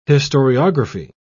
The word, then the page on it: historiography: British English